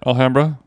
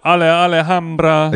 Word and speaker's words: Alhambra: Alhambra. – Alhambra. – You called it Ale Alhambra.